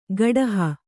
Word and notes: ಗಡಹ: gaḍaha

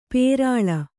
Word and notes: ಪುರುಳಿ: Kannada

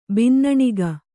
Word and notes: ಬಿನ್ನಣಿಗೆ: Kannada

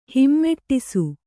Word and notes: ಹೂಮುಡಿಸು: hū muḍisi

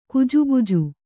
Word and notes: ಗಜಬಜೆ: gajabaje

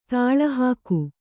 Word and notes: ದಾಳಿಹೋಗು: Kannada

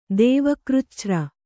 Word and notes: ದೇವಕೃಚ್ಛ್ರ: kannada

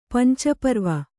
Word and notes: ಪಂಚಪರ್ವ: panca parva; Kannada

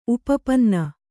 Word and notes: ಉಪಪನ್ನ: upapanna

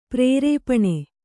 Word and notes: ಪ್ರರೂಪಣ: prarūpaṇa; kannada

